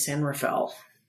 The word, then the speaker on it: San Rafael: So there's probably the Spanish pronunciation is probably closer to the more syllables than fewer, but the way we pronounce it here in these parts is San Rafael.